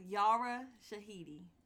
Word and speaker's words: Yara Shahidi: Next, we have Yara Shahidi.